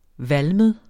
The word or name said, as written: valmet